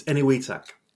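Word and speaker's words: Enewetak: It's Eniwetak.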